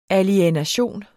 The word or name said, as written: alienation